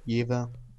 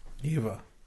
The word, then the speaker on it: Jever: It's from a German speaker. – Right. So it's a pronunciation that is probably more correct than we would say it. – Jever. Jever. – Jever. Jever Pilsner.